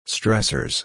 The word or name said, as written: stressors